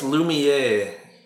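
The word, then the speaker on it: Lumière: It's Lumiere. Lumiere. – Lumiere. – Lumiere. – Lumiere. – Yeah. – Did you ask Google how to? – How do you spell it? – Lumiere. That's why, because it was spelled wrong in my notepad Lumiere. – Thing. – Lumiere. – It was spelled Lemure.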